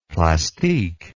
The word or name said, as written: plastique